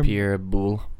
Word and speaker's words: Pierre Boulle: Pierre Boulle.